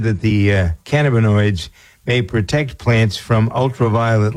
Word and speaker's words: cannabinoids: And so we have, with this research that's being done, opened the door for developing pesticides from cannabinoids, Cannabinoids, yeah, that's how you pronounce it.